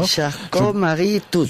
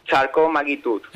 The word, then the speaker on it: Charcot-Marie-Tooth: Charcot-Marie-Tooth. – Supongo sí. Que tú lo pronunciarás mejor. A ver, dila tú mismo. – Charcot-Marie-Tooth.